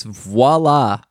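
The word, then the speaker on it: voilà: I hate it when Americans pronounce French words wrong. Like, voila. Have you heard people do that? – That's like a, they're like mixing in some Russian. – Yeah. – When you pronounce a V as a W. – It's voila, Americans. It starts with a V.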